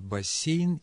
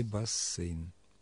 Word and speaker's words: бассейн: Орфоэпический словарь Ирины Леонидовны Резниченко разрешает оба варианта – бассейн и бассейн.